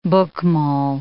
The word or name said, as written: Bokmal